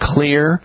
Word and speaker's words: Xlear: It's called Clear Rescue Nasal Spray. Clear Rescue Nasal Spray. And clear is spelled with an X. Janice, X-L-E-A-R, Rescue Nasal Spray.